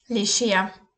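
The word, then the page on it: lescher: Bavarian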